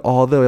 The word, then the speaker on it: although: 那最后这个 although 要注意一下，它的发音跟前面的 think 跟 thought 不太一样。